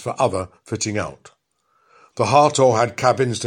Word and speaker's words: Hathor: It was called Hartor, spelt H-A-T-H-O-R, but pronounced Hartor, and named after the Egyptian goddess of love and joy of the sky and of the west.